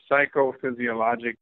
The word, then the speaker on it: Psychophysiologic: The Psychophysiologic Disorders Association.